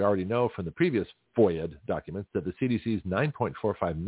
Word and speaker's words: FOIA: Gutzkow, who has secured numerous FOIA documents, that's F-O-I-A, they call it a FOIA, which is a pronunciation of Freedom of Information Act, F-O-I-A acronym.